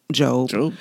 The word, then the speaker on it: Job: the book of Job is about Job. And it is spelled J-O-B. It is not pronounced Job. It's pronounced Job.